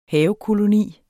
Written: havekoloni

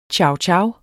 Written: chowchow

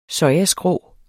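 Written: sojaskrå

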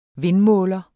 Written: vindmåler

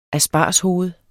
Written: aspargeshoved

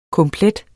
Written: komplet

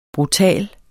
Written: brutal

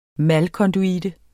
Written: malkonduite

